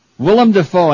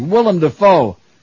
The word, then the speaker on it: Willem Dafoe: Willem Dafoe, and I said it right this time. Let's hear it. Oh, practice makes perfect. Willem Dafoe, star of Mississippi Burning and Platoon.